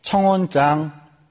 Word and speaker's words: 청혼장: 청혼창 아, 발음을 잘해야 되는데 교회는요 청혼장 좀